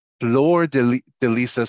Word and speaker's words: Fleur de Lisa: I am your estate planning and elder law attorney, and I am joined today by Lisa Balthaser of Fleur de Lisa Solutions. Did I say that correctly? – Yes.